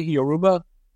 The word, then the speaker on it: Yoruba: Now, you told us a little bit about Yoruba. Am I pronouncing that correctly? Yoruba? – It's Yoruba. Yoruba.